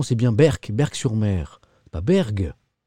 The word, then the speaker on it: Berck: Ah, on dit Berg. On dit pas Berck.